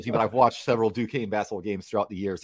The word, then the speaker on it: Duquesne: Is it Duquesne? What? Am I saying it wrong? – Yeah, it's Duquesne, and how do you know this? – Because I follow their basketball team.